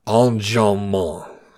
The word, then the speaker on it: enjambement: For my contribution, I'd like to point to a very poem-specific term that students might not have heard of, called enjambment. It's a French word, and if you want to be nice and Frenchy in your pronunciation, go and hold your nose and pronounce it enjambement.